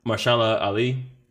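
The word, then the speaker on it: Mahershala Ali: So we watching season 3 of True Detective, because our man's help me pronounce this, like, for real, because I don't know how to say it right. Mashallah Ali. – That sounds good to me.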